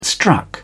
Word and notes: struck: British English

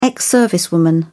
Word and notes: ex servicewoman: British English

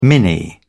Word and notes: mini: British English

